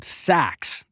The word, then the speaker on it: Saxx: I recently discovered this brand of underwear called Saks. That's spelled S-A-X-X.